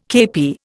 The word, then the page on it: kepi: Indian